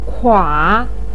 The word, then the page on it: kua: 3